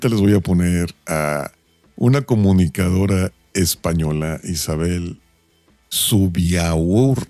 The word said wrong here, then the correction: Subiaurre: ¿Cómo se, cómo se pronuncia, güey? Isabel Subiaurre. Subiaurre, bueno. Isabel Sub Subiaurre es una presentadora en España y es que en Canarias pues hizo erupción un volcán.